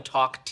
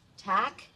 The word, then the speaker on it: talk: I'm sorry, did we start spelling talk, T-A-W-K, and no one told me? No. Try it again. – Okay. Talk. – Don't pronounce the L, just suggest it. – Tack? – Please tell me that was a joke.